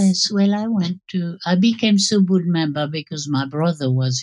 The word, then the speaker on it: Subud: So, for those people who don't know about Subud, which is spelled S-U-B-U-D, Subud, tell us a little bit about it.